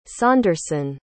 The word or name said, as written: saunderson